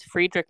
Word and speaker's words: Friedrich: So it's spelled Friedrich. Friedrich. But he's German, so I just assume Friedrich, which is, I think, the German way to spell Frederick, which is my father's name.